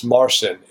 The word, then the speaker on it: Marcin: His name is, I'm sure I'm getting the Polish pronunciation wrong, Martin Owczyk. It's Owczarek. Yeah, he does these wonderful, and it's Marcin, Marcin.